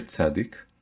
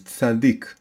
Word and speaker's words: tzadik: You take the letter tzadik, and you pronounce the name of the letter. The name of the letter is tzadik. Sometimes it's called tzadi, sometimes it's called tzadik. So tzadik means a righteous man, a righteous person.